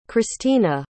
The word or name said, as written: Krystina